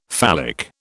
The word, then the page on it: phallic: British English